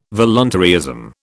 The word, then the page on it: voluntaryism: British English